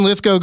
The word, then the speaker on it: Lithgow: It's Judd Apatow and John Lithgow, so it gets very confusing when they're running around with the same spellings at the end but different pronunciations. – Didn't know that. – But for some reason, Lithgow goes with go, and he was on with Paul Tompkins and Jimmy Pardo.